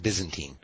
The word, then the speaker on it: Byzantine: So, and you get a whole lot of, like, the Crusades and, like, Catholicism versus Islam and, you know, but just so you know, it's pronounced Byzantine.